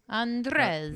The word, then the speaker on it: Andrzej: Andrzej.